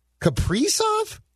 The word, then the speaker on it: Kaprizov: No, it's not Kaprizov. It's Kaprizov, I believe, or Soft. When the Wild signed him, they issued the pronunciation guide. It's not Kaprizov. We've been saying it wrong.